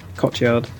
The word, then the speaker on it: Cotillard: How do you say that name? – Yeah, I think that's right. Cotillard.